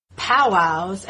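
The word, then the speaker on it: powwow: They would have powwows, accent on the wow, in bathhouses.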